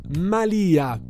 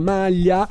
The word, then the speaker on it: malia: La malia di una strega, non la malia, non la maglia.